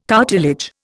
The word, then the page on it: cartilage: Indian